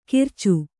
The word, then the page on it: ಕಿರಚು: Kannada